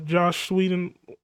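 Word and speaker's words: Joss Whedon: Like the Josh Whedon. Am I saying it right? – Yeah.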